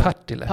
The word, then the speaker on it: Partille: Ja, eh, Partille.